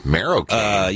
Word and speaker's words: marocaine: Marocaine. – Yes. – Marocaine. – Here is the pronunciation here by Merriam-Webster. – Marocaine. – Marocaine.